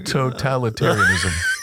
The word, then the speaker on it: totalitarianism: Oh, the emphasis on the wrong syllable. – Oh, I did it wrong. – Totalitarianism.